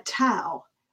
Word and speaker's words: Tao: So when people look at the word T-A-O, they typically say in America, Tao, which it can be pronounced that way, but it's really pronounced Tao, like the Dow Jones, D-O-W, but it's spelled T-A-O.